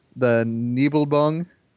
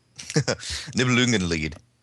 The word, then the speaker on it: Nibelungenlied: but the Nibelbung. – Nibelungenlied.